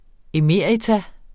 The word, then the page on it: emerita: eˈmeˀɐ̯ita